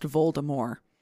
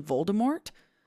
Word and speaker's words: Voldemort: It was supposed to be pronounced Voldemort. – Yeah, it was supposed to be a hard T.